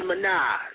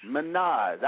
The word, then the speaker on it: Minaj: Nicki Minaj. Did I say that right, too? – Nicki Minaj. – Minaj. I was going to say that. I knew it.